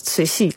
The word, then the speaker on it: Cixi: Tenminste, je spelt het als C-I-X-I, dat is lastig. Dat spreek je uit als C-C. Maar er stond in het boek, stond C-C-I.